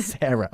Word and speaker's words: Sarah: Decimos nosotros Sarah. Yo lo dije mal, pero Sarah.